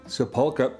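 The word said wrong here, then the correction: Sapulpa: Sepulpa, Oklahoma.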